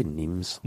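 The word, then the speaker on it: Nîmes: I would say Nimes. – Nimes? – Yeah. – Nimes sounds like not French. – Nimes. They've got a lot of S's at the end of things they don't bother pronouncing.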